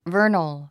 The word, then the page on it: vernal: və’ː r nl; ヴァーナル